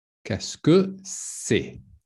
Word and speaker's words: qu'est-ce que c'est: So phonetically, it would be something like this. Like, qu'est-ce que? So this sound doesn't exist in English, but it's kind of like, ah, qu'est-ce que c'est?